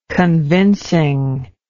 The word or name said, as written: convincingness